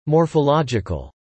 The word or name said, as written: morphological